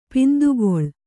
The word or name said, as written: ಪಿಂದುಗಳೆ